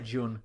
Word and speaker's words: Jun: J-U-N. How do you pronounce it? I'd say Jun.